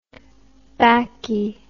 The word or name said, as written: bakkie